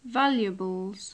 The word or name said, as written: valuables